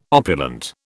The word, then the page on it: opulent: British English